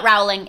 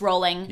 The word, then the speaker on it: Rowling: It's not Rowling, it's Rowling. – Yeah, as in bowling.